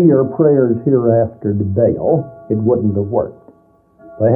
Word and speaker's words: Baal: One, the Hebrew word Baal, which in English we spell B-A-A-L and generally pronounce Baal, and the other was the Hebrew word Adonai.